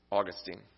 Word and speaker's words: Augustine: Her son was Augustine, or Augustine if you prefer,